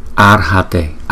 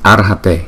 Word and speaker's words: Arhate: Arhate, Arhate, Arhate. Cái chữ R này chúng ta đọc là chữ R, chữ ra nhưng mà không có đọc rõ chữ ra mà là Ar, Arhate, Arhate, Arhate.